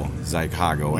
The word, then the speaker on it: Xicago: And I started with the, I'll call it Zycago. Or Chicago, Zycago. Yeah, yeah, yeah. It's a play on words, obviously. It's spelled X-I-C-A-G-O, Zycago.